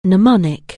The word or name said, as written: mnemonic